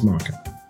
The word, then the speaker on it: maca: It's pronounced maca.